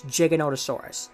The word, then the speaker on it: Giganotosaurus: And as such, the correct way to say this dinosaur's name will be to include both O's and to pronounce the G like a J. So it's Giganotosaurus. That's how you say its name.